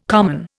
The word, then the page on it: common: Indian